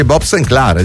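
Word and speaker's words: Sinclar: Ragazzi, ma si dice Bob Sinclair, delle due è francese, non è inglese. Chiaro, se fosse inglese si leggerebbe Bob Sinclair, ma è francese e quindi Bob Sinclair.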